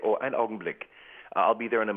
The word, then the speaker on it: Augenblick: The German term, ein Augenblick.